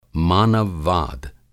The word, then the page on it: मानव-वाद: Hindi